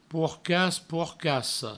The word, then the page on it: porcas, porcassa: pourcas, pourcasso; provençal